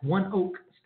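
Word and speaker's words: ONEOK: So again, United fell to Tulsa Roughnecks at, I heard it's pronounced on the broadcast, One Oak Stadium.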